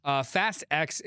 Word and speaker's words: Fast X: How are most people pronouncing it? Fast X or Fast 10? – Fast X is what is like officially...